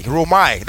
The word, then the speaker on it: rhoomai: rehoma. It's spelled R-H-O-O-M-A-I. Romai. That's what the Greek word says.